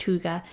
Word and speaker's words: hygge: If you haven't heard it before, it's spelt Haig, pronounced hygge.